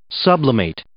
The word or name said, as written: sublimate